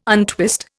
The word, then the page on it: untwist: Indian